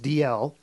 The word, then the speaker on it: Dalziel: It's spelled D-A-L-Z-I-E-L, but it's pronounced D-L, who, along with his new partner, the Oxford-educated Pascoe, get involved in movie-length cases, each with its own milieu.